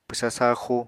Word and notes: pwsa saaxu: apatani

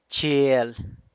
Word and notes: chiel: San Lucas Quiaviní

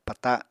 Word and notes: pata: Apatani

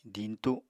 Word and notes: diñtu: apatani

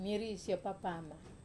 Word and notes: mari so papama: wamesa

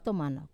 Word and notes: tomanau: Wamesa

